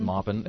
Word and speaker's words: Maupin: Am I pronouncing it? Is it Maupin? Maupin? – Maupin. M-A-U-P-I-N? – Yeah. – I think so, Maupin.